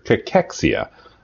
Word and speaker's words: cachexia: It's cancer cachexia. I think I'm saying that right. Dr. Dressler, what are your thoughts on it? And am I pronouncing it correctly? – Well, you are pronouncing it correctly.